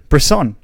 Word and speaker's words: Bresson: Is that how you say it? – Bresson. – Bresson. Oh, okay.